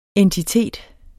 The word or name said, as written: entitet